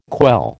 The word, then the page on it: quell: American English